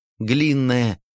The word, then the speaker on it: Глинная: «Правильно. Ударение на первый слог. Глинная».